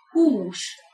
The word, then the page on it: hus: Hungarian